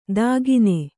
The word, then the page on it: ದಾಗೀನೆ: dāgīne